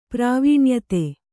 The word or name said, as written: ಪ್ರಾವೀಣ್ಯತೆ